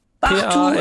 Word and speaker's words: Partout: Partout, also Französisch.